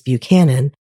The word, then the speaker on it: Buchanan: Though originally named for the 15th American president, James Buchanan, I am in fact pronouncing the county like the locals do. So please spare me your pronunciation emails. I have a neighborhood in my hometown pronounced Buchanan instead of Buchanan in the same way, so it isn't even an unusual regional pronunciation.